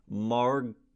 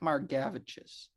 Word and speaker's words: Margevicius: So according to Baseball Reference, it is Nick Margavichus. And it is spelled Margevicius. – Nick Margavichus.